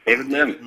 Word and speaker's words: David Mihm: David Mihm.